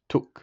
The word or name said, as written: took